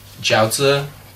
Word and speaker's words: jiaozi: Like, one of my favorites isn't specific to Anhui, but Juro Jiaozi, if that's correct. I think I'm just saying pork and then jiaozi. That's how you say it, actually.